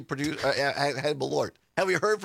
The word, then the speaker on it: Malort: So Malort and somebody, Julie or Jules in Mosinee, listening on WXCO, says, are you sure it's not pronounced Merlot? I actually looked it up, Jules, because I didn't know, and that's why I found that video of this guy reviewing it. And no, this is, it's spelled differently than Merlot. It's M-A-L-O-R-T, and it's pronounced Malort.